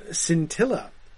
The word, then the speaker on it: scintilla: Tom Hillman says that scintilla rhymes with Priscilla.